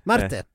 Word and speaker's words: Marte: Men Marte hade som sagt hon hade kommenterat det här och du uttalar det här som Marta. – Marte. – Ja. – Du menar Marta som är hon brasilianskan. – Ja. Det känns ändå mer norskt med Marte.